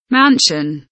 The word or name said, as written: mansion